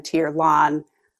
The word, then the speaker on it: Tirlán: And so one of the things we did with this name was we dropped the fada over tier. And there are a number of reasons for doing this. One of them is we felt that it would help aid pronunciation for people who were not familiar with these words. See it over the A at the end of the name to sort of help them understand Tirlán, where that emphasis lies.